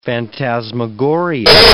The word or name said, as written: phantasmagoria